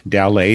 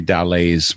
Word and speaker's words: Dallet: Dallet is spelled like ballet with a T on the end, but with a D at the beginning.